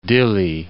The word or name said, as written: Dilli